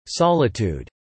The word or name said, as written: solitude